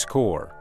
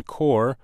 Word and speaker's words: Corps: 今天我们要学的词是 Peace Corps. Peace is spelled P-E-A-C-E, peace, and corps, C-O-R-P-S, corps, Peace Corps.